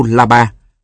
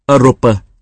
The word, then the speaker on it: Europa: Ví dụ, ơ sô bơ là châu Âu La Ba, Ferdinand de Lesseps, đào sông Suezer. Ông nhai đi nhai lại, mãi mà vẫn quên, thỉnh thoảng phải bắt mình nhắc hộ. Mình đọc là Europa, châu Âu. Ông không thể nào bắt chước được.